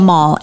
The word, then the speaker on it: Amal: Amal. A-M-A-L.